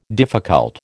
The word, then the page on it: difficult: American English